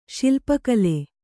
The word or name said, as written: ಶಿಲ್ಪಕಲೆ